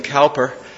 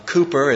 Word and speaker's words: Cowper: wonderful words by, and I will intentionally mispronounce it, William Cowper. Cooper is the way that often that's pronounced, but Cowper sets his name apart.